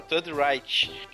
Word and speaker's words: Third Reich: Third Reich, não, Third Reich.